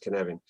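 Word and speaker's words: Cynefin: it's sort of spelt Sinofen, but it's pronounced Kinevin.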